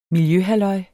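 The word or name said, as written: miljøhalløj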